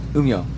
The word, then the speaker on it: Umeå: Umeå. – Yeah, I can't pronounce that. – No. – Umeå.